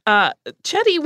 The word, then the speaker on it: Chetti: I'm not sure how the Norwegian language shift would have changed the pronunciation of his name, so we're going with the Italian version. Chetti was an interesting figure in his own right.